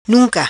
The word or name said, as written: Nunca